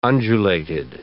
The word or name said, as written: undulated